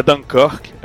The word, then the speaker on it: Dunkirk: mas o filme continuou com o nome original, então é Dunkirk, é como se pronuncia.